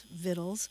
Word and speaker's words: victuals: and it's spelled V-I-C-T-U-A-L-S, Vittles, and it's a love letter to the cuisine and the folkways and the foodways and the history and language of Appalachia.